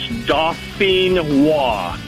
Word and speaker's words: Dauphinois: It's Dauphinois.